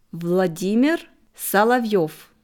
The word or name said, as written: Vladimir Solovjow